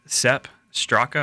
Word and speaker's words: Sepp Straka: It came out Sepp Straka. I'm probably saying that wrong. What do you think there, Wyatt? – You're all good.